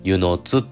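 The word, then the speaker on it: 温泉津: 私、母の生まれがですね、島根県の湯の津というところです。温泉に津って書いて、湯の津って読みます。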